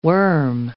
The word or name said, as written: worm